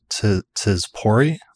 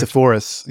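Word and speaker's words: Sepphoris: This is the one from Tzipori. Am I pronouncing that correct? – Sephorus. Yeah, Sephorus. – Sephorus, okay.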